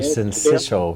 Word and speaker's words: syncytial: Respiratory syncytial virus.